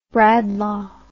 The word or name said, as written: Bradlaugh